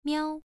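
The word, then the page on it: miao: tone 1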